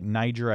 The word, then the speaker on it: Niger: The country is spelled N-I-G-E-R. Looks like Niger. I believe it's Niger, though.